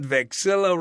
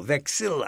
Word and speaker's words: vexilla: Non licebat vexilla Romanorum furari. – Furari are the flags. – Furari is to steal. Vexilla are flags. – Vexilla. – Vexilla. V-A-V-E-X-I-L-L-A.